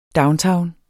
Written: downtown

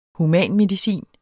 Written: humanmedicin

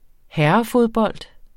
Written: herrefodbold

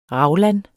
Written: raglan

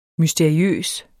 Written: mysteriøs

